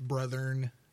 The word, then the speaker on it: brethren: It's brethren. You're adding an extra syllable.